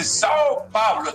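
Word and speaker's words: São Paulo: Dice Sao Pablo. ¿Está bien dicho así? – Perfecto. Lerninho fala portugués extraordinario.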